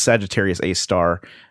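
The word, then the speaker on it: Sagittarius A*: Apparently Michio Kaku says that it's pronounced Sagittarius A star, and he's the guy that I guess knows about that stuff, so I'd go with him.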